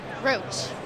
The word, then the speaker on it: Roche: Roche.